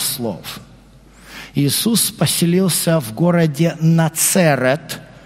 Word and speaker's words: Назарет: Отсюда название города Нацерет. Но так как в греческом языке нет буквы «ц», то они его произносят как «Назарет».